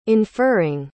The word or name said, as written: inferring